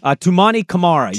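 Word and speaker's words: Toumani Camara: Tumani Kamara, is that how you say his name? – Tumani Kamara, yeah.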